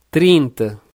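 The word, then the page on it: 30: Brazilian Portuguese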